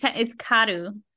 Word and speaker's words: Kaoru: So their R's are almost kind of pronounced like a D. So it's Karu.